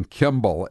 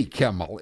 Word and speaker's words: Kimmel: The number of people pronouncing Kimball and not Kimmel says it all.